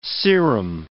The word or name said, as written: serum